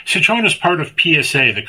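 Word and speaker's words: Citroën: Well, real quick, speaking of foreign cars, a listener says, I was in Rome last week and came across the Citroen. What? I can't pronounce it. – Citroen. – Yes.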